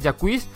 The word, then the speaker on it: Jacquees: Él tiene un nombre así un poco extraño, Jacquees, escrito Jax o algo así, se podía pronunciar de la forma, pero la pronunciación buena es Jacquees.